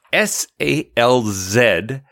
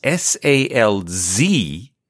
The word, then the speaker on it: Salzbach: It's Salzbach. Now, for those of you in America, it's Salzbach.